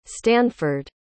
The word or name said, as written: stanford